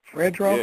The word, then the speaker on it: Fredro: Is I say his name, what is it, Fredro Starr? What's the nigga name? – Fredro? – Yeah, you right.